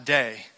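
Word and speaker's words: day (δεῖ): And I say little because it's based on a Greek word that's three letters. It's pronounced day.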